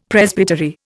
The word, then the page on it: Presbytery: Indian